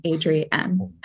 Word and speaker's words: Adrienne: it it's uh, at um, A D R I E underscore E N N E, which is also a hint as to how to pronounce my name, Adrienne.